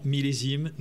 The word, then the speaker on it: Millésime: How do you pronounce that? – Grand Blanc, Millésime.